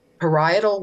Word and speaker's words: parietal: Parts of his brain, like they said, I think it was I'm going to butcher this pronunciation because I'm no biologist, but it's the parietal lobes. Is that, would that be it? – Right.